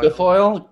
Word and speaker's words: Guilfoyle: I think that's right. It's like Guilfoyle. – Guilfoyle.